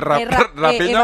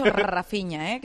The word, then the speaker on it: Rafinha: No, Rafinha, ¿cómo se dice? Rapinha o Rafinha. – Evangelio me ha dicho Rafinha, que es Rafael.